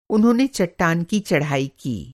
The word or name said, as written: चढ़ाई